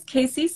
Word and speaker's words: Kaci: Yeah, my name is Kaci Smith. My first name is spelled K-A-C-I, and I live in Richmond, California, which is in the Bay Area outside San Francisco.